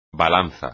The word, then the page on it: balanza: espagnol